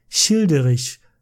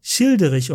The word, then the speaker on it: Childeric: Already in the year 463, his son Childerich ruled. Childerich, or Childeric, how you say it in English, is a historically secured person.